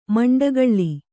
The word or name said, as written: ಮಂಡಗಳ್ಳಿ